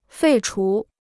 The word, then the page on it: 废除: fèi chú